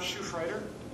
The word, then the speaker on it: Schufreider: My name is Tom Schufreiter. It's spelled S-C-H-U-F-R-E-I-D-E-R.